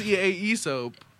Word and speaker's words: Aesop: Oh, Aesop. Aesop. – Is that it? – His name's Aesop. That's a literary joke for all my people who like literature, because Aesop is spelled A-E-S-O-P. Aesop.